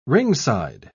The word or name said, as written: ringside